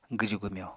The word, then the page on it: gwju-gwmyo: apatani